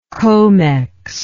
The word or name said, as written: Comex